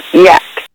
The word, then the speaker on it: Jack: Jack in Spanish is pronounced 106.7 Jack FM. – It's pronounced yak.